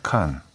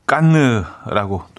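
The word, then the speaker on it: Cannes: 칸. 네, 칸 영화제. 어, 우리나라 영화제 아니죠? 네, 칸 영화제. 어, 깐느라고 또 발음하기도 하고요. 깐느.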